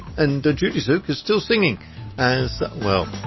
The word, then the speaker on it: Tzuke: And it's spelled T-Z-U-K-E, but the T is sort of almost silent, so it's Judy Zook.